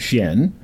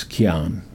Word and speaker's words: Qian: C-H-I-E-N. Right? It's not Shen. It's Qian. It's basically, and sometimes you'll see it spelled Q-I-A-N.